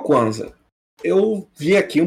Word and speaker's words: Kwanzaa: Isso mesmo, Kwanzaa.